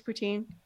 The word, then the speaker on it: poutine: Skates, and is it poutine? Is that how you say it? – Yeah, skates, poutine, put on your toque.